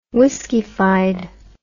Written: whiskeyfied

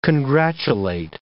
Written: congratulate

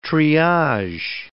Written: triage

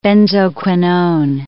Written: benzoquinone

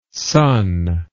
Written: sunn

